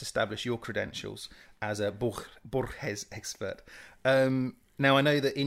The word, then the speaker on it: Borges: I think it's Borges, the right pronunciation. Do you think you can do it? – Borges. So there's more of a rolling of the R.